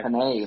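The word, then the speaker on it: Penei: Panay, like the pasta.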